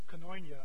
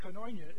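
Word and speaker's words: koinonia (κοινωνία): So say coin, and there's an on switch. Coin on. Yeah. Well done, you're all Greek scholars. So this is koinonia.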